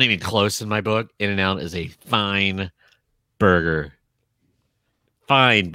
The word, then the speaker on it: In-N-Out: It's not even and, is it? It's In-N-Out. In-N-Out? – In-N-Out. In-N-Out. – In-N-Out. – In-N-Out. – In-N-Out. – In-N-Out, yes. – Here is the deal. – It is three syllables.